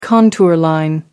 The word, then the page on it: contour line: British English